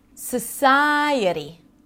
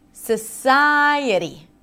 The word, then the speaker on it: society: Society. Ta-da-da-da. Again. Da-da-da. So hold out the primary stress. It also helps practice the primary stress, but really focus on the other syllables as well this time. Society. Society. Season.